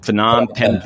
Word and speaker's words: Phnom Penh: And this is very interesting, and serving as managing editor at Cambodia's, and I'm going to mispronounce it because I've never had to say it, Phnom Penh Post. Is that right? – That's right.